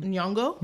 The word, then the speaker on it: Nyong'o: Nyong'o, I never know how to say her last name. – Nyong'o.